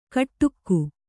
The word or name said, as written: ಕಟ್ಟುಕ್ಕು